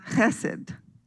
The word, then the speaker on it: chesed: It is a Hebrew word, and it's pronounced chesed.